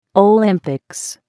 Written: Olympics

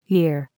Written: year

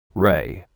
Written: ray

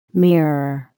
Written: mirror

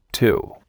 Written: Two

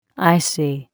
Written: icy

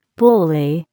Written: bully

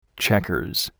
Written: checkers

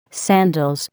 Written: sandals

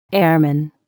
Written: airman

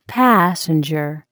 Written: passenger

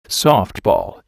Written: softball

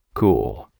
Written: cool

